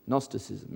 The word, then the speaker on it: Gnosticism: Silent G, Gnosticism.